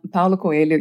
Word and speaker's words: Paulo Coelho: Paulo Coelho.